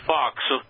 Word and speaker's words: faux: And in English, we would pronounce it like Fox, almost, even though it's faux, of course.